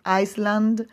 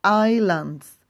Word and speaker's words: islands: Check out the pronunciation. It's not Iceland, but islands.